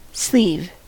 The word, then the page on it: sleeve: American English